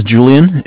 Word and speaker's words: Julian: My name is Julian. It's J-U-L-I-A-N. And I'm calling from Bank of Cardiff. Cardiff is spelled C-A-R, D as in dog, I-F-F as in Frank Frank.